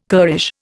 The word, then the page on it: currish: Indian